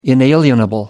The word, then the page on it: inalienable: American English